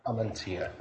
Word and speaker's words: Amantia: Is it Mr. Amantia? – Amantia. – You put the emphasis on the wrong syllable.